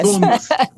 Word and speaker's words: Bournemouth: Bournemouth. – Yes. – Bournemouth.